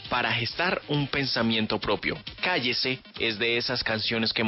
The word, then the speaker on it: KYC: estos días Bubblegum nos presenta nueva canción, nos presenta Cállese, que se escribe K-Y-C, tres letras que dan título a este nuevo sencillo de Bubblegum.